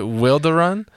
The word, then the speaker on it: Wilderun: Is it Wilderun? – You are saying it right.